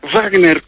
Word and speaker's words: Wagner: Yo decía, por ejemplo, Tolen, eso era Beethoven, naturalmente, y aprendí a pronunciar Wagner con toda la gana de una pronunciación alemana, Wagner, como la pronunciaba él con todo ese gusto.